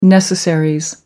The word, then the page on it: necessaries: American English